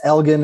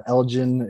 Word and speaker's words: Elgin: It's Elgin. It's not Elgin.